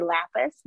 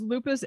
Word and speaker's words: Lupas: So in saying things, pronouncing things differently, he said it's L with an anarchy sign, but wouldn't that just be Lapis? Not Lupus? – No, it was Lupus, L-U-P-A-S.